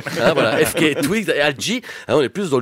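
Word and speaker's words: alt-J: et l'autre s'appelle Alt G. ALT plus loin J.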